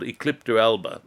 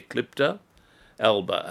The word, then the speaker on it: Eclipta alba: It's called Eclipta alba. I'll spell it for you. E-C-L-I-P-T-A, Eclipta alba, A-L-B-A.